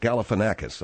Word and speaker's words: Galifianakis: That's not his name. It's not Zach Alakazam. It's Zach Galifianakis, okay? – Yeah, well, now that you look.